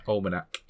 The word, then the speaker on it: almanac: The gambling, the Omnimac, is that how you say it? – Almanac.